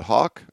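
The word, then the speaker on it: Houck: Coach, coach Hudson, is it Hawk or Hook? Hawk. Hawk.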